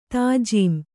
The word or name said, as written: ತಾಜೀಂ